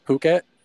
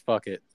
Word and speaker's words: Phuket: Oh yeah, you said Phuket. That's the right way to say it. – Mm-hmm. I don't know. – I always thought it was fuck it.